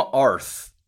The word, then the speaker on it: Arth: Tom Arth.